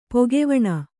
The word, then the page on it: ಪಕ್ಕಿವಣೆ: Kannada